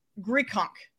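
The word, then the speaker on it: Grichuk: I like him to have success because the Rockies just traded away 2 of their best hitters, in CJ Krohn and Randall Grichunk. Did I say that right? – I believe so.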